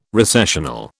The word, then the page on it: recessional: British English